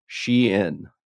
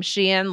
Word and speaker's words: Shein: And this company, according to the story, is pronounced Shein. Is that correct? – That's right. So I first started seeing the name Shein like on my social media feeds.